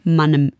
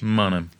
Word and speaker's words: Mannheim: Und ich hatte mal einen Freund, der da aus der Gegend kam und weiß deswegen, darum habe ich es eben gesagt, dass Mannheim von den Leuten, die dort wohnen, Mannem im Dialekt ausgesprochen wird. Richtig? – Mannem.